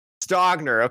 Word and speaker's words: Stogner: Stogner. – Okay, I want to say Stoner, but I was like, I can't.